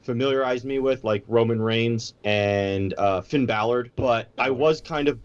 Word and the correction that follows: Balor: No, it's B-A-L-O-R, Balor.